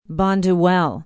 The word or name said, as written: bonduel